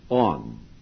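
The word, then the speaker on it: on (ἄν): Now, I have a technical explanation of the Greek in the Passover book showing that there is a Greek particle spelled A-N or alpha nu, which is pronounced on.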